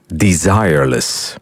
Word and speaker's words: Desireless: Blijkbaar moet je het wel op zijn Engels uitspreken. Desireless.